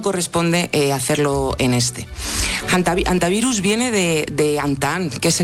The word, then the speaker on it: hantavirus: No es infrecuente oírla quizá de ambas formas, pero lo adecuado es en efecto dejarla muda, decir hantavirus y no aspirarla, hantavirus, ni nada similar.